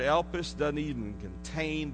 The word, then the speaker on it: elpis: The word hope in the New Testament is from a little Greek word, and it's pronounced elpis. If we transliterate it, that means write it in English so we can spell it or say it. It's E-L-P-I-S.